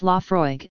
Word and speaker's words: Laphroaig: Laphroaig, pronounced Laphroaig, is located on the southern coast of the island.